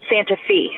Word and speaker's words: Santa Fe: It looks like Santa Fe, spelled exactly the same way, F-E at the end, but they pronounce it Santa Fe. – Santa Fe? – Is this real life or is it just Santa Fe? – Santa Fe, Tennessee. – Oh, it rhymes.